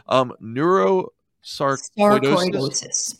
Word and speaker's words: Neurosarcoidosis: Neurosarcoidosis.